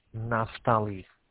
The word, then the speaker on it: Naftali: Naftali.